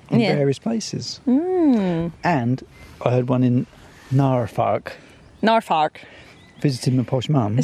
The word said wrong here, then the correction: Norfolk: But Norfolk.